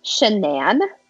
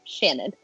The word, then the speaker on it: Shanann: So, Chris met his wife, and I know I'm probably going to pronounce her name wrong, like, a bunch of times, because it's Shanann. But I always want to say Shannon. But it's Shanann.